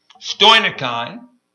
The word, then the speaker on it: stoicheion: I'm going to spell it, S-T-O-I-C-H-E-I-O-N, stonikein.